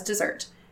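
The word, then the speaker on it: deserts: The phrase just dessert is pronounced as dessert and spelt with only one S.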